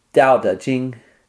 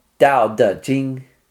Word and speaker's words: Dao De Jing: That's pronounced with a D? – Yeah, that's not really the Taoist's fault, but when they started to write Chinese into English letters, there was a lot of mistakes made.